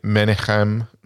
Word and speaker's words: Menachem: The studio was purchased by Pathé Communications and Menachem.